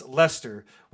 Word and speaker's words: Leicester: And I want to thank Mike for first sharing the band's music with us, but second for teaching me how to pronounce Leicester, which is L-E-I-C-E-S-T-E-R.